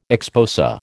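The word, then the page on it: exposé: American English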